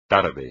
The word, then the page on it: tarde: espagnol